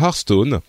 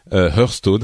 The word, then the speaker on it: Hearthstone: c'est ce court métrage sur Hearthstone, Hearthstone, Hearthstone, les deux prononciations sont valides,